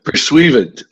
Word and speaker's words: pursuivant: Pursuivant.